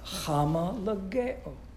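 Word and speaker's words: hamalageo (ὁμολογέω): This word right here is the actual word that is translated confess, and it is pronounced hamalageo. Hamalageo. And it literally means same words.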